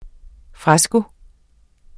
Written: FRESKENS

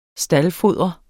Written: staldfodre

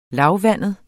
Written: lavvandet

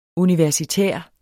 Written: universitær

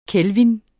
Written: kelvin

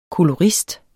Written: kolorist

